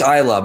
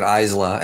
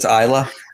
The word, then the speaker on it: Islay: I'm told it's Islay, but I've been pronouncing it Islay, and I've been told that's wrong. So apparently it's Islay.